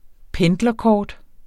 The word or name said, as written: pendlerkort